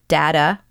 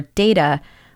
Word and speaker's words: data: The word data or data, we've used it interchangeably in this episode, and they are actually both correct. They're just different ways of saying them.